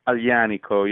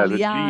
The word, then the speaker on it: Aglianico: Please tell me the correct pronunciation. I'm not quite sure. I studied French growing up, not Italian, although there's a lot of similarity. Is it Aglianico? – Aglianico, yeah. Aglianico. The GL in Italy is a soft sound.